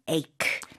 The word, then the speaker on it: ache: A-C-H-E 있지만 발음은 ache. – H 아닙니다.